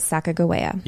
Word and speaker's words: Sacagawea: So we're gonna pronounce it Sacagawea. – Yep. – Just the way you would pronounce it if you were learning how to pronounce things. All right. Because the Lewis and Clark Journal pronounced it this way.